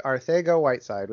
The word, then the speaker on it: Arcega-Whiteside: Arcega-Whiteside, which apparently that's how it's pronounced. He's got the Spanish in there.